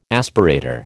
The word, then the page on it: aspirator: American English